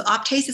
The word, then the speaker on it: Optase: So one of my brands that I really like at the moment is called Optase. So Optase is spelled O-P-T-A-S-E.